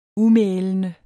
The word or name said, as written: umælende